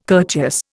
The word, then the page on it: courteous: Indian